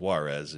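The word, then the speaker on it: Juárez: It's immediately north of the Mexican city of Juarez, Ciudad Juarez. Called. That's how they say it in Spanish, or just Juarez is usually the way we Americans would say it.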